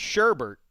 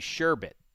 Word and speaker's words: sherbet: It is not sherbet like most people say. – Yes. – It is sherbet. There is no second R, even though most people think there is.